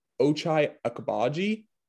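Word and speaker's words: Ochai Agbaji: And Ochai Akabaji is how you say his name, but he's just a versatile scorer.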